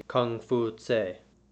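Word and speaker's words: Kong Fuzi: This episode is about one of the most influential men in history, Confucius, or his more authentic pronunciation, Kung Fu Tse.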